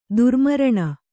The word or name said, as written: ದುರ್ಮರಣ